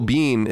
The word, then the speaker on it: Biehn: The movie would not have worked no matter who played OJ, because Michael Biehn, is that how you say it? I think that's how you say it. – Yeah, right? – He's awesome.